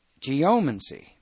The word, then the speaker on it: geomancy: Now, I have heard four or five different ways of pronouncing this. Now, let's get my boss's pronunciation. Geomancy.